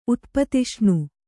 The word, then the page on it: ಉತ್ಪತಿಷ್ಣು: kannada